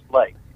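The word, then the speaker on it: Leake: It's spelled like Leak, but it's pronounced Lake. – Okay, Lake. – It's L-E-A-K-E, but it's pronounced as Lake. That's how Mr. Lake pronounced his name.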